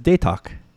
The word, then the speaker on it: Datoc: And I would say that, you know, my brother-in-law, his last name is Datok. D-A-T-O-C. – D-O-C. Mm-hmm. Okay. – But they pronounce it as Datok.